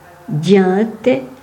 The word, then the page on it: Juentes: [ˈɟɛ̃tɛ]; Arpitan; greverin